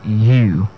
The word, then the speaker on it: ewe: Yes, and this word, E-W-E. Interestingly, this word people pronounce as Ewe. And I remember one of our senior, the WAG exams, saw the word, and a lot of students could not pronounce the word. Yeah, and the word is simply pronounced as U. Just the same way you pronounce your Y-O-U. Yes, you. That's the way it's pronounced. And the second word is the word Y-A-C-H-T.